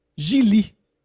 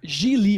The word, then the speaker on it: Gigli: Ça se prononce Gigli. Gigli, oui, c'est ça. Gigli. – rhymes with really qui dit.